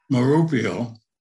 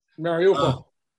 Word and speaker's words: Mariupol: Mariupol. No, I got the pronunciation wrong. You know what I'm referring to. – Mariupol.